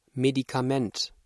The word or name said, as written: Medikament